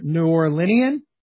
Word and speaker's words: New Orleanian: Eddie is a native New Orlean. I'm not sure I'm saying that right. New Orleanian? – Yeah. – Okay. A native New Orleanian.